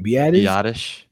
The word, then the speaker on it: Biadasz: And then you're going to say Tyler, what, how do you say his last name? – Biadasz.